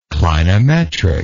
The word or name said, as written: clinometric